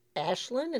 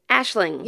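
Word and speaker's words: Aisling: I wanna tell everybody because Ashlyn, is that how you say her name? – Ashling. – Yeah, Ashling. Mm-hmm. Or Ashlyn. Ashlyn. – But Doyle. – It's A-I-S-L-I-N-G.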